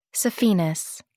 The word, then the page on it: saphenous: American English